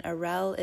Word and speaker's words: Arelle: And Arel is spelled A-R-E-L-L-E, last name H-U-G.